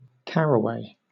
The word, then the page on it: caraway: British English